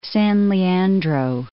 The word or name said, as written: San Leandro